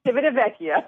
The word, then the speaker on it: Civitavecchia: It docks about an hour and 45 minutes outside of Rome in Civitavecchia. Did I say that right? – Very good. I couldn't tell if it was the static on my phone, but it's Civitavecchia. – Yeah, correct. – Absolutely. So your article, 8 Hours in Rome, talks about the highlights, what to see with only one day to explore.